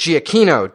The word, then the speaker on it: Giacchino: He says it's pronounced Giacchino, damn it.